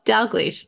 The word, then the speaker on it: Dalgleish: Did I say that right? Say it, Tracy. – Dalgleish. Close. Dalgleish.